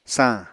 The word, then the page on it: sañ: Apatani